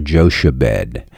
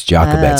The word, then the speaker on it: Jochebed: Or they mispronounced it in the Ten Commandments. They called her Jochebed. It's Jochebed. Oh. C-H in the Old Testament is a K sound.